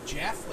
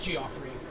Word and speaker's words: Geoffrey: is it pronounced like Jeff? Like Jeffrey? And he was like, no, it's Geoffrey.